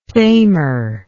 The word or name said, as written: thamar